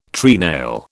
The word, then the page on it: treenail: British English